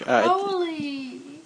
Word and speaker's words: Crowley: Crowley! – Oh, God. Did I say that? I'm sorry. – Crowley rhymes with holy, not Fowley, Crowley.